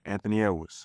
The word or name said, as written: Anthony Edwards